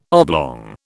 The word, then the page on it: oblong: British English